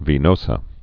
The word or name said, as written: sinus venosus